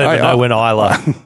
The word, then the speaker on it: Isla: I think it's Isla. – Isla. – Isla. – I don't know. – Well, I do. It's Isla. It's like Isla Fisher, the actor.